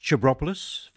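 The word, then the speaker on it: Tsabropoulos: Chabropoulos, that's actually spelt T-S-A Bropoulos, but my friend from swimming at Coogee Beach every morning, Con, assures me that it's pronounced Chabropoulos, Vasily Chabropoulos, Greek pianist, classically trained jazz musician, and Anya Lechner on the violin cello there.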